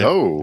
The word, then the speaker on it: Zoe: It's Zoe, right? – Zoe. – Yeah, just Zoe.